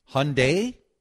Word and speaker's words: Hyundai: Is it Hyundai? I thought it was Hyundai. If I did, I apologize. Am I mispronouncing? – I've always gone Hyundai, but a lot of people say Hyundai, Hyundai too. Yeah.